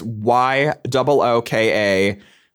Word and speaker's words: Yooka-Laylee: It's Yooka-Laylee. So it's like phonetically spelled out.